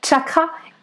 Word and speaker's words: chakra: Je tiens également à préciser qu'en sanskrit, chakra se prononce chakra, le premier chakra donc.